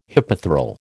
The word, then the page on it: hypethral: American English